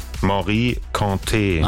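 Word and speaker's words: Mory Kanté: Wie wird's ausgesprochen? – Morikante. – Ach so, guck mal. Es war also falsch.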